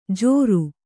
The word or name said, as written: ಝೋರು